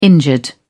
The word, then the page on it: injured: British English